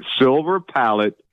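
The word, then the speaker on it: Silver Palate: I eat silver plate. – Silver palette pasta sauce. – Pasta sauce, that's right. – How do you say it, by the way? Silver plate. No. – Silver palette pasta sauce.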